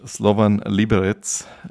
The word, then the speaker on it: Slovan Liberec: mit 36 Jahren nochmal tschechischer Meister mit Slovan Liberec.